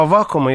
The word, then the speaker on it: Аввакума: Что касается протопопа Аввакума, я все-таки настаиваю, чтобы произносить именно с таким ударением.